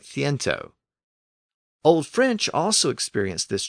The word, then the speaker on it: ciento: Except in Spanish, the K sound became an S sound before the E and the I. So the Latin word centum evolved within Spanish and became C-I-E-N-T-O, which is pronounced as ciento, with the S sound.